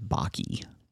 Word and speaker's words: Bakke: Bake. I can't pronounce that name. – They typically pronounce it. I mean, it's not like I knew the person, but I think they typically pronounce it as Bakke.